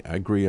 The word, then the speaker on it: Agre: There was a Washington Post article last week about a guy, Philip Agri, I think is the way you pronounce his name, is A-G-R-E, Agri, I'm assuming, saying that in 1994 he predicted the dark side of the internet.